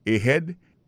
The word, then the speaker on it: ahead: Ahead. Ahead là, c'est A-H-E-A-D. Ahead. Ahead. Go ahead. Là encore, on a vu que accent tonique là tombé sous deuxième syllabe.